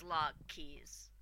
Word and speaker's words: Lochees: He confirmed that I was pronouncing it right as Lockheed's. – Well, yeah, and if you listen to the audio tapes, which I recommend, it's Lockheed's. Lockheed's.